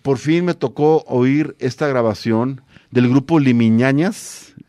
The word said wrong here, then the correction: Limiñanas: De ahora lo digo mal, Limiñanas.